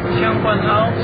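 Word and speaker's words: Tseung Kwan O: How do you pronounce T-S-E-U-N-G? How do you pronounce Xing Kuan O? – Xing Kuan O? – Xing Kuan O, that's it.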